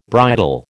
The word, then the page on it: bridle: American English